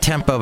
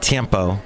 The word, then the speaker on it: tiempo: Not tempo, but tiempo. There we go. I'm saying it right, I think.